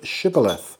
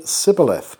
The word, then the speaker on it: Shibboleth: And if he said no, they would tell him to say the word Shibboleth. But he would say Sibboleth because he couldn't pronounce the word rightly.